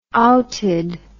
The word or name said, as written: outed